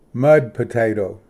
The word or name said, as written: ᏄᎾ ᎠᎪᏗ ᎠᏁᎯ